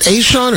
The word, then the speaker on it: ASEAN: Do they pronounce it Aishon, or I don't think there's another way to pronounce that. ASEAN, the Association of Southeast Asian Nations. Yeah, I think that's the way it's kept pronounced.